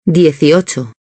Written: 18

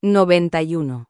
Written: 91

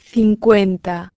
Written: 50

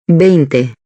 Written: 20

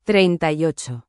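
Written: treinta y ocho

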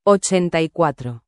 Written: 84

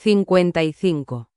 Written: 55

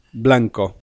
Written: blanco

